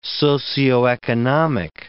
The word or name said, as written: socioeconomic